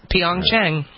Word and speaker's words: Pyeongchang: Pyeongchang? – Is that how you pronounce it? – I think we just did it. We just did it. Pyeongchang.